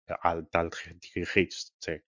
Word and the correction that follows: Alltagsgeschichte: o como se diga? – Pero Alltagsgeschichte, creo que se pronuncia más o menos así, Sí.